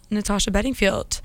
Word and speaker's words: Natasha Bedingfield: But enjoy some Natasha Bedingfield.